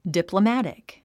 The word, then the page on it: diplomatic: dìpləmǽtik; ディプロマティク